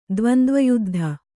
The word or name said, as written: ದ್ವಂದ್ವಯುದ್ಧ